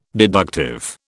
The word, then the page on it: deductive: British English